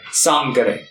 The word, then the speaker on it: Sangre: Is it Santa Sangre or Santa... – Sangre. – Sangre. Okay.